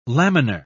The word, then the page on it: laminar: British English